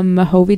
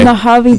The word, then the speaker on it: Mojave: I filmed out in the Mojave Desert. I think Mojave. I used to call it Mojave. I'm still saying it wrong. Oh, my God. – It's okay. – Mojave Desert.